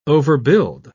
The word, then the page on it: overbuild: British English